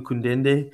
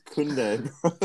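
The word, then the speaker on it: Koundé: We still waiting on Kundende. I know I said that wrong. That was on purpose. Kundende. I don't know why I'm calling him that, but yeah. – His name's Jules Kunde.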